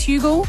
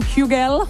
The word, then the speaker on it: Hugel: His name is Hugel. Oh, I'm saying it wrong. Hugel.